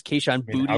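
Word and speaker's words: Kayshon Boutte: I'm going to go Kayshon Booty. – Yep. – I think that's the correct pronunciation I've been seeing on the in the Twitter sphere, is Kayshon Booty.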